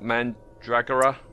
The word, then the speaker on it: Mandragora: Well, the next time he was in something genre-wise, it was Doctor Who again, The Mask of Mandragora, did I say that right? – Yes.